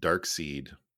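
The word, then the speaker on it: Darkseid: I do have one correction. It is pronounced dark seed.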